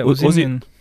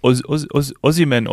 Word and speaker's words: Osimhen: Wie wird er ausgesprochen? Äh, äh Osimien. Osimien, Osimien.